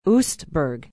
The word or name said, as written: oostburg